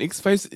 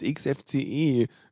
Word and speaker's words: XFCE: irgendwann davor vorbeikamen und meinten so, ja, hey, seid ihr nicht die von X-Face? Nee, es ist XFCE.